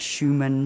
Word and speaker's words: Schumann: I see there's Mozart, Schumann, Schubert.